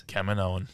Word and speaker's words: Kaminoan: And the correct way to say it is? – Kaminoan. – Kaminoan, according to TikTok.